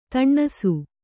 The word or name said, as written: ದಿನಸಿ